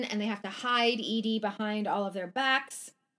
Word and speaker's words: Eaty: And they settle on the name Edie. E-A-T-Y, and they pronounce it like Edie, like the name Edie.